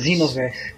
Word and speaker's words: Xenoverse: Xenoverse. Você pronuncia com Z, como se fosse Z.